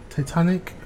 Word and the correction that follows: tectonic: plates? Is that how you say it? – Tectonic plates? – Yes.